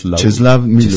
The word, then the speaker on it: Czesław Miłosz: Aunque, como dice también Milost en esta, ¿cómo se pronuncia? – Czesław Miłosz.